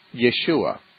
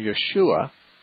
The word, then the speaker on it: Yeshua: Well, the name Jesus in Hebrew and also in Aramaic, the language that he and his society spoke, was Yeshua. And that Hebrew pronunciation, Yeshua, is transliterated in the Greek New Testament as Jesus.